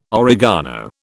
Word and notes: oregano: British English

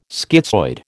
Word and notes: schizoid: American English

